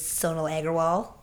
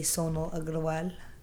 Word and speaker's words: Sonal Agarwal: That's how it's regionally pronounced. I guess at home we say Sonal Agarwal, and then it's always a thing with the name, like, am I pronouncing it correctly? It's like, there's no right or wrong.